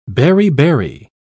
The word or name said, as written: beriberi